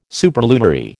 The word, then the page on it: superlunary: American English